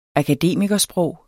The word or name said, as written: akademikersprog